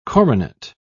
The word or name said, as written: coronet